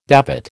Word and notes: davit: American English

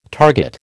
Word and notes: target: American English

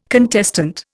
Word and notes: contestant: Indian

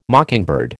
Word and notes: mockingbird: American English

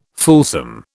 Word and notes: fulsome: British English

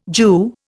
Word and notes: Jew: Indian

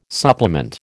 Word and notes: supplement: American English

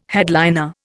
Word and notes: headliner: Indian

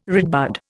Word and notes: redbud: Indian

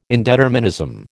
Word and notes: indeterminism: American English

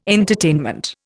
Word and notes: entertainment: Indian

